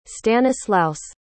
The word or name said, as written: stanislaus